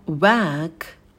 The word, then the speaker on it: work: Далее слово work, work, работать. На звуке w вытягиваем губы, потом звук э, расслабленный долгий шва, как в слове girl, девушка. И в конце звук к произносим с придыханием.